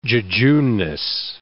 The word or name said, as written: jejuneness